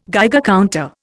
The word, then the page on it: Geiger counter: Indian